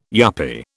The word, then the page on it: yuppy: British English